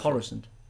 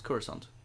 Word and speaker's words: Coruscant: What would you go Coruscant. – For? It's Coruscant. – Coruscant. – It's pronounced both ways. – Okay. – Actually pronounced both ways in the movies as well.